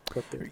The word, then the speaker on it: Côte d'Ivoire: Côte d'Ivoire. – So. – Côte d'Ivoire. – Côte d'Ivoire. – Yes. Côte d'Ivoire.